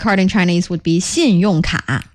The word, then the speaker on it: 信用卡: Are you using two syllables there? 信用卡啊? – No, 卡 is one syllable.